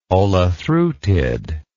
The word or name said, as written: olethreutid